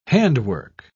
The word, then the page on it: handwork: British English